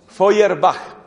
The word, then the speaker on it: Feuerbach: Si usted mira el libro, La esencia, se llama así, La esencia del cristianismo, y el autor es Feuerbach, que se escribe, pues es alemán, entonces se escribe Feuerbach, exacto, B-A-C-H, Feuerbach.